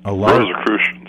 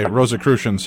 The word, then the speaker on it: Rosicrucians: but are you familiar with the Rosicrucians? – Rosicrucians. – Okay, Rosicrucians.